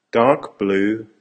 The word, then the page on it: dark blue: American English